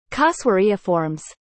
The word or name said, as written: Casuariiformes